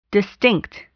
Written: distinct

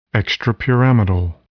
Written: extrapyramidal

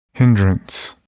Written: hindrance

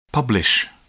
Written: publish

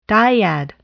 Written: dyad

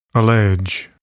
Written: allege

